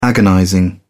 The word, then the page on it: agonizing: British English